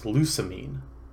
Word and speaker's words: Lusamine: I thought we pronounced it Losamine. – No, it's Lusamine.